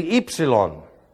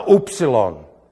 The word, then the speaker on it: ypsilon: This is the ypsilon, not the upsilon. This does not have an oo sound. It has an e, a long e sound.